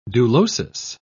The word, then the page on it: dulosis: British English